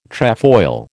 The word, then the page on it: trefoil: American English